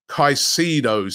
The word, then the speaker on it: Caicedo: And as I said on yesterday's show, Caicedo seems to be back on Arsenal's radar. That's how you pronounce it.